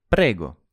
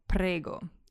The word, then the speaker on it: prego: And Matteo replied, prego. – You're welcome. And here's our first example of prego.